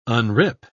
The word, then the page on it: unrip: British English